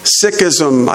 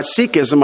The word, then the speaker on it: Sikhism: Most Eastern religions are built upon the concept of pantheism, whether it's Hinduism or Sikhism. I used to call that Sikhism. I've learned the correct pronunciation is Sikhism.